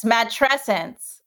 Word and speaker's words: matrescence: Yes, matrescence. It's like adolescence. That's how I remember how to pronounce it.